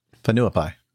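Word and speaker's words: Whenuapai: Fenuapai. – Actually, that's not so bad. So the WH is pronounced with an F.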